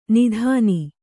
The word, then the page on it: ನಾದಿನಿ: nādini